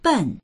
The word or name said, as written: ben4